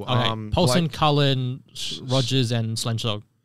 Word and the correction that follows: Schlenzog: Is that how you say it? – Schlenzog. – Schlenzog.